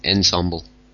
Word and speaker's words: Ensemble: For Emblem, or an Embl, that's how you say it, isn't it? An Embl. – An Ensemble.